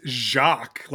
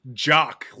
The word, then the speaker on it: Jock: Yeah, I don't even think it's Jacques, like Frère Jacques. I think it's Jock, like football player, basketball player, varsity jacket Jock. – It's spelled J-O-C-K, so.